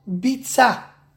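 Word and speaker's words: Bitza (ביצה): They both spell the same, Beis Yud Tzadik Hei, but you don't pronounce them the same. When you talk about the egg which is Safno Me'ar, heat from the ground, is called Bitza.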